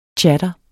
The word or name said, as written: chatter